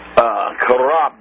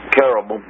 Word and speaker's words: cherubim: Karab is also a pronunciation for them. Cherubim. CH is pronounced either with an SH or a K, and both are correct.